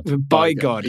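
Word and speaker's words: Bigod: Well, you've pronounced it... – Bygod. You can say Bygod. It's all right. – Baron Bygod, Baron Bygod.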